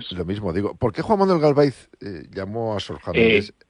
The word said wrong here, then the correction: Galaviz: Sí, antes que nada, una corrección. Es Juan Manuel Galaviz Herrera. – Disculpa, digo la pronunciación. – Galaviz, sí.